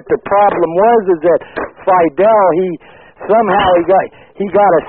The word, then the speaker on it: Fidel: Yeah, man, Fidel. That's how you say it.